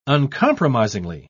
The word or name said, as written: uncompromisingly